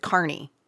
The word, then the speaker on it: Kearney: And then Kearney, you pronounce it Kearney. That's where I stayed. Kearney is where I stayed. After my 1K last year, I got there at 2 o'clock in the morning. – Right. – But it's spelled K-E-A-R-N-E-Y, like Kearney. And I think it was Becky who told me, no, it's Kearney.